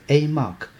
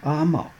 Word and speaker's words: AMOC: 那我们在表达的时候，为了简化，我们就说它是 AMOC，但是你也会在广播或者节目里面听到有人说它是 AMOC。 AMOC 跟 AMOC 只是发音的不同，看你来自于哪个地区，那都是指这个北大西洋经向翻转环流。